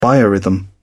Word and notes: biorhythm: British English

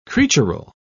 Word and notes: creatural: British English

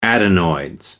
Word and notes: adenoids: American English

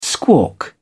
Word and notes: squawk: British English

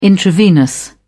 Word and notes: intravenous: British English

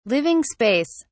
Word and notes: living space: British English

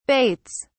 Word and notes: baits: British English

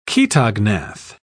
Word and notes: chaetognath: British English

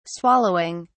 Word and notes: swallowing: British English

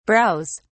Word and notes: brows: British English

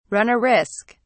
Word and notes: run a risk: British English

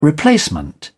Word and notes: replacement: British English

